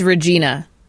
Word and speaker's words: Regina: It's Regina. – It's the city that rhymes with fun.